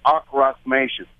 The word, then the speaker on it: Ankhrasmation: It's a music language called acrostication. – Yeah, okay. Tell us a little bit about that. – That's spelled A-N-K-H-R-A-S-M-A-T-I-O-N.